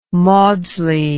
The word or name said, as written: maudslay